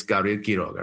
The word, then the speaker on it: Gabriel Quiroga: my name is a Spanish name, right? It's Gabriel Quiroga, right? That's it, right? – Sure.